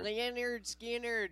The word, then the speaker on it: Lynyrd Skynyrd: Lynyrd Skynyrd.